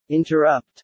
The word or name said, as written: interrupt